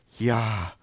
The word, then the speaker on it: Yahweh: but instead is simply the sound of breathing. Yod, hey, vav, hey, ya, ha, wa, ha. Sounds something like this. Yahweh. Yahweh, Yahweh. Try it with me. Yahweh, Yahweh.